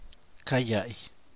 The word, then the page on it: ca yall: Macuilxochitl Zapotec